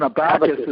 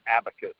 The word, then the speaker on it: abacus: Yeah, no, I get it, but you just pronounce it different than what I learned. Yeah, I learned it as abacus. Okay, cool. – Yeah, I guess I learned it as a Bacchus.